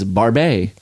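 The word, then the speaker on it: Barbet: I also learned how to pronounce is Barbe.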